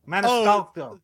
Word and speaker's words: Maniscalco: Sebastian Manschiallo, is that how you say it? – Jesus Christopher Cross. – I mean, how do you say it? Help him out. Maybe he'll send you tickets. – Maniscalco. Gotcha. I'm fucking it up. Your mispronunciation was so bad that mine has permanently been altered.